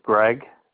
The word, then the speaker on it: Greg: You said your name is Gary, G-A-R-Y? – No, it's Greg. – Greg, G-R-E-G. – G-R-E-G.